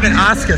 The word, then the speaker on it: Asuka: So what was it like to wrestle over at WWE against Asuka? – Against Asuka? – Asuka, sorry. – She might kick your head off too if she knew you pronounced her name like that.